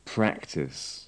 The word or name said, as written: practise